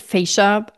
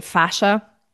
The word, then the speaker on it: fascia: Some pronounce it fascia. I prefer saying fascia.